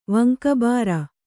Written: ವಂಕಬಾರ

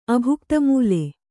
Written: ಅಭುಕ್ತಮೂಲೆ

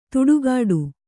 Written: ತುಡುಗಾಡು

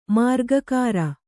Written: ಮಾರ್ಗಕಾರ

